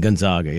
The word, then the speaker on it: Gonzaga: Like a gun. Gonzaga.